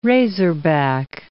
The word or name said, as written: razorback